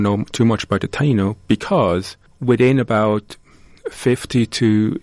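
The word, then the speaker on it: Taíno: And so when he did land on those islands, he encountered the native people there who are called the Taino, is that correctly? – Some of them, yeah. – Pronounced? – Yes.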